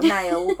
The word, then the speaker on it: Nail: Spelled Nail, but I'm going to guess it's probably pronounced Nile.